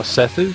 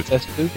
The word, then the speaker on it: Seth: That is correct, and that's how they pronounce it in the game, too. – Oh, Sesu? – Sesu. – Sesu? – Yeah, there's no TH sound, as I understand right. – it, in Japan.